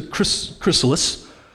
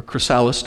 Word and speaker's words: chrysalis: Like what happens when the caterpillar enters the chrysalis, or chrysalis, depending on which syllable you emphasize, the chrysalis.